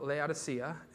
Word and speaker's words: Laodicea: And in the last church that we're covering, the church of Laodicea, is how you say it, is covered all of these.